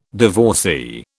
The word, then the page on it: divorcee: British English